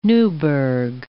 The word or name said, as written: Newburg